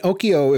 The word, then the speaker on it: Okyo: And Okyo, if you're listening, is spelled like Tokyo, but without the T. So O-K-Y-O.